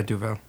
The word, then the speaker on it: Duvel: Referring to their potent alcohol strength as a tribute to the original example, Duvel. Is that, am I saying that right? – Yeah, Duvel.